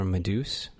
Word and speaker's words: Meduse: I went for Meduse because the spelling of Medusa is with an A.